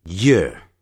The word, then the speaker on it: year: 我们来看 contrast 比较比较有 y 加元音或无 y 加元音的念法我们来看一下年 year year year year 记住如果你把前面的 y 的音去掉 air air 是耳朵年的时候念成 ear ear 所以耳朵 ear ear 再把它念一下年 year year 耳朵 ear ear 有差别的哦